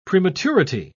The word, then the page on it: prematurity: British English